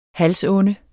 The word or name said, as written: halsonde